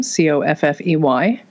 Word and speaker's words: Coffey: Coffee is spelled like my last name, Coffey.